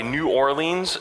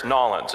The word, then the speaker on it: New Orleans: I was actually on a mission trip into Louisiana, near Nolens, which I could tell it was from the north because I would say New Orleans as two separate words. And I found out when I was there, it's Nolens. It's all one, and that's how you say it.